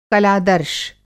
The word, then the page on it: कलादर्श: Hindi